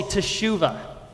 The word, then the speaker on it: teshuva: Let me hear you all say teshuva.